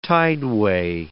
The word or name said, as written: tideway